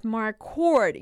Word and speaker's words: Marquardt: So Vitor Belfort fought Nate Marquardt. Yes, that's how you pronounce Nate's last name.